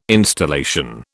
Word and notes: installation: British English

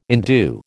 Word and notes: indue: American English

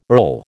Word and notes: earl: American English

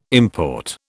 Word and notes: import: British English

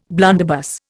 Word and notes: blunderbuss: Indian

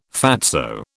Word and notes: fatso: British English

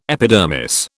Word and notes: epidermis: British English

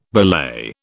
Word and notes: belay: British English

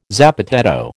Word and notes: zapateado: American English

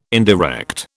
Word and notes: indirect: British English

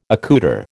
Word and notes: accoutre: American English

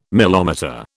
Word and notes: milometer: British English

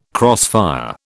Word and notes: crossfire: British English

